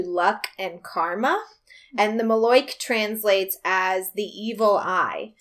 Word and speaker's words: malocchio: So it's spelled M-A-L-O-C-C-H-I-O, but it's pronounced Maloik. All which, right. – What? – All right. – Why? Why isn't it Malakio? – So, yeah, no, right?